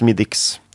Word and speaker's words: Smithwick's: Stavas ju Smithwick's. – Mm. – Men det lär uttalas Smithwick's.